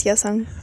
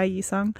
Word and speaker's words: Yesung: It's Yesung. – Yeah, Yesung. – Yesung.